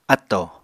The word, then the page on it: áttò: Apatani